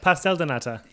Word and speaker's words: Pastel de nata: Pastel de nata.